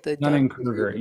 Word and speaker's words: Dunning-Kruger: Because I think even like you were mentioning the Dunning, how do you pronounce that? – Dunning-Kruger. Yeah. – Yes. Yes.